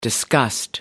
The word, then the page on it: disgust: American English